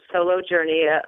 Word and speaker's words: Soulo Journey: Am I saying that right? – Yes. So S-O-U-L-O, Soulo Journey, obviously a little play on words.